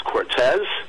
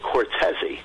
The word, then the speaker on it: Cortese: In fact, I played in a band with a top drummer called Gary Cortez back then, and the name was spelled C-O-R-T-E-S-E. Now, I know from knowing you and other individuals, I've heard it now pronounced both Cortez. Now, a lot of times people, ladies and gentlemen, will spell Cortez C-O-R-T-E-Z. Okay, our guest is C-O-R-T-E-S-E. So I'm sure there's people that maybe from old school will call him Gary Cortez. Or after you meet him and his wife and everybody else, you'll know that they actually pronounce it Cortez-y.